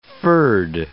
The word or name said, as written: furred